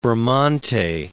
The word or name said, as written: Bramante